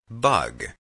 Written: bug

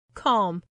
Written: calm

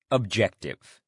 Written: objective

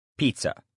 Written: pizza

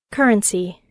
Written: currency

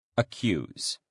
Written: accuse

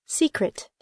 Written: secret

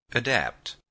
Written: adapt

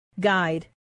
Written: guide

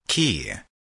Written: key

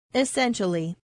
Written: essentially

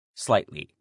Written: slightly

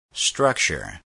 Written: structure

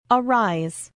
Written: arise

